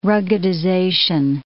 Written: ruggedization

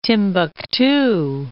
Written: Timbuktu

